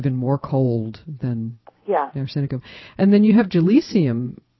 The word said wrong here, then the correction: Gelsemium: which I usually think of. – Gelsenium. – I'm saying it wrong. Okay, say it again. – Gelsenium. – Gelsenium, I'm saying it wrong. G-E-L-S-E-M-I-U-M.